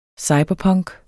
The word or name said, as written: cyberpunk